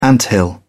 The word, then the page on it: anthill: British English